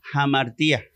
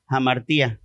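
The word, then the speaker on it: hamartia: aunque se, se escribe amartía, suena como jamartía, como que es una pequeña aspiración es una aspiración este, eh, suave que tiene la palabra, amartía.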